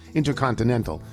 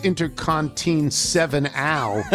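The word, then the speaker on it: Intercontinen7al: Once again, we are playing original tunes by Intercontinental, but Intercontinental is spelled I-N-T-E-R-C-O-N-T-I-N-E-N, and then the number 7 is put in there, A-L, but it's pronounced Intercontinental, because if it was pronounced Intercontin-7-Al, nobody would know what in God's name you were talking about.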